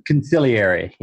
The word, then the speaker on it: consigliere: Am I saying it right? It's like the godfather. – Consigliere. Yeah. – Consigliere, there we go, right?